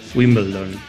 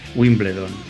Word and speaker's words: Wimbledon: No hay nadie por ahí que le pueda explicar a Zubi que se pronuncia Wimbledon y no Wimbledon.